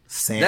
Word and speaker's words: salmon: Every time he opens his mouth, she's just like, salmon. – That's because he talks... Remember, the L is silent.